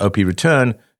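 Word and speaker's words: OP_RETURN: Bitcoin Mechanic adds that while data spam, like Ordinals, already harms the network, actively endorsing larger OP return makes such abuse commonplace, turning Bitcoin into a junk drawer for gross media or malware. And, you know, I gotta... OP return is pronounced op, like opportunity.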